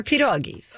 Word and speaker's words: pierogi: Or pierogies. – Oh, there it is. – Pierogies. Like pea doggies. Pierogies. – Now, if you called it pea doggies and you're like, sorry, that's what I call pierogies, that would make much more sense to me.